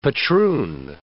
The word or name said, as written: patroon